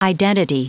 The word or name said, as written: identity